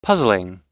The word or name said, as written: puzzling